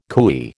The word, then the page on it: cooee: American English